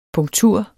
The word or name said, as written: punktur